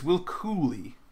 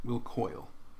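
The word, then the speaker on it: Cuylle: It's Will Cooley. Cooley. Not Will Coyle. It's one of those names where it's spelled in a way where you think maybe it's Coyle, but it's Cooley.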